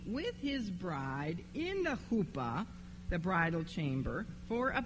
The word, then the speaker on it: chuppah: And then the couple would go into a private chamber, which is called the chuppah, which I may be pronouncing wrong, but that's the way I pronounce it. It is H-U-P-P-A-H. How would you pronounce that? Chuppah.